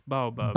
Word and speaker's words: baobab: I saw one, how do you pronounce this word? Baobab? – Yeah. It's Baobab.